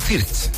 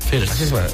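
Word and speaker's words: Wirtz: Nos vamos al ataque, Leroy Sané, Jamel Musiala, Florian Wirt, no, es Florian Fiertz. Fiertz. Florian Fiertz, así Fiertz se pronuncia.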